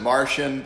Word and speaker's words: Marcion: There's a guy in you could pronounce it Martian, but I don't. I think it's it always sounds funny, but it would be appropriate.